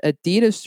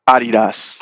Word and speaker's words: Adidas: Or if so you're European, Adidas. – Adidas, yes. Sorry, I gotta ask for forgiveness for my German people for mispronouncing there.